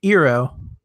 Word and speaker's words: Eero: or the Eero, that's how you say it, right? – Yep, yeah, you're good.